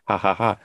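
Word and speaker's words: jaja: And I remember the first time I saw that, my friend, I was like, cha-cha-cha. And I was like, oh, right. No, it's Spanish pronunciation. Ha-ha-ha.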